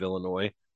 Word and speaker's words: Illinois: But a couple of months ago, we talked about the super mayor, Tiffany Henyard from Dalton, Illinois, because you got to pronounce the S at the end of Illinois.